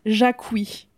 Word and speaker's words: Jacquie: c'est la girl boss incarnée et elle s'appelle Jackie mais on le prononce Jacqui à chaque fois parce que ça s'écrit J A C-Q-U-I-E. Euh, à la française.